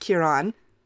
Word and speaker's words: Ciarán: In the 95 adaptation with Ciarán Hinds, which I have to make a quick note here, it's pronounced Ciarán. Thank you to our lovely Irish listener, Maeve, for correcting me on my anglicized Irish pronunciation of my true love, Ciarán Hinds.